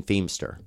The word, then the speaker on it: Feimster: That is the right way to say it. – Oh. – Yeah, because how did you say it? – I didn't know. I said it was either Feimster or Feimster. – It looks like Feimster, but it's Feimster.